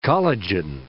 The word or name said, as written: collagen